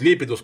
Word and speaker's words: Лепидус: Нет, он не будет Липидус, он будет Лепидус, потому что ударение на второй слог в данном случае быть не может, потому что он открытый, поэтому короткий, а ударение будет на длинный слог, то есть на лепидус.